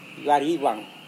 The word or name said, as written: warivang